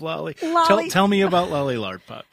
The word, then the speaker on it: Lardpop: I hope I'm pronouncing that correctly. Yes, Lardpop.